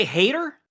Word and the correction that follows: Haener: It's not Hader. How do you say it? – Hainer. There's Hainer. No T in there. – And yeah, there's no T. Hainer.